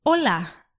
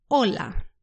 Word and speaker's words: hola: The H is silent in Spanish. So all words in Spanish, they've got an H, you just don't pronounce it. For example, when we say hello, we say hola.